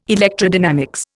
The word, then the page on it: electrodynamics: Indian